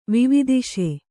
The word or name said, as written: ವಿವಿದಿಷೆ